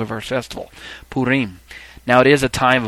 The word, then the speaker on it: Purim: The festival known as Purim, and my pronunciation there, Purim, is not like the usual pronunciation that you're going to hear in many circles. In fact, you'll hear it called Purim, Purim, Purim, Purim, something to that effect. It is, in fact, correct to say it as Purim.